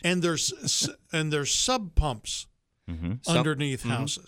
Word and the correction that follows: sump pump: Sump pump, basin, French drain.